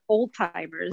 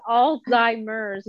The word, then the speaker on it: Alzheimer's: Well, I had someone like back in the day call it old timers. – Right. – Like, what? Yeah. And no, it's Alzheimer's with a Z. Alzheimer's. Alzheimer's.